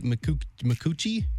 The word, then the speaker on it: Micucci: Big Bang Theory, Kate Micucci? – Yeah. – Is that how you say it? – Yes. – It's M-I-C-U-C-C-I.